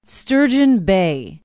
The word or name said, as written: sturgeon bay